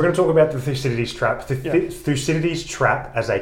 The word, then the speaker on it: Thucydides: We want to talk about a couple of concepts that come from Thucydides.